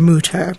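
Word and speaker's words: Mütter: And when he returned from Paris, he had an umlaut, and he decided from that point on, you will call me Dr. Mutter. And so I honor him by pronouncing it that way, but I do get corrected a lot by people who say in the South, it's called Mutter. – It's Mutter, but here in Philadelphia, it's Mutter.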